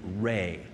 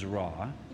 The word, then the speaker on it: Re: In ancient Egypt, the sun was worshipped as a deity named Re, commonly mispronounced as Ra,